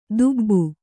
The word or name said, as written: ತೂಬು